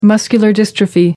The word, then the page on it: muscular dystrophy: American English